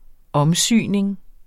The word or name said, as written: omsyning